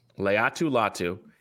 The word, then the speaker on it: Laiatu Latu: How is it pronounced? – Laiatu Latu.